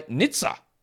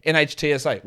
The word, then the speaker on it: NHTSA: Today's episode is brought to you by NHTSA, which I've been told apparently is how it's said. I don't know if that's true or not. NHTSA, whatever.